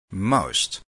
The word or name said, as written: most